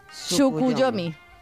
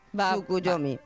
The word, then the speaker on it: Tsukuyomi: Tsukuyomi. – Wow. – Digo yo que se dice así, tal vez. – Seguro que es Tsukuyomi.